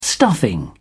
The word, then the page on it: stuffing: British English